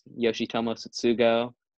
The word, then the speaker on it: Yoshitomo Tsutsugo: Oh, two other guys that are candidates, Yoshitomo Tsutsugo for the Rays and Shoko Akiyama.